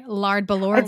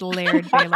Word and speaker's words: Laird Balor: I'm sorry, Lard Balor going. – Really, really good. – I know it's Laird Balor. – I was like, wait, Lard?